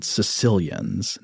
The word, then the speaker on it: Caecilians: Now, remember in the episode we did about amphibians with special guest Mark Mandica, he was discussing these amazing creatures called Caecilians, not the people from Sicily. – Right. – But it is spelled C-A-E-C-I-L-I-A-N.